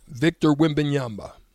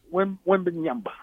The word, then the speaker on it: Victor Wembanyama: Do you know how to pronounce it? – Victor Wembanyama. – Wembanyama. Okay, that's what I thought.